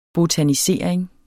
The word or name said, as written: botanisering